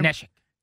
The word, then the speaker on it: Neshek: Neshek.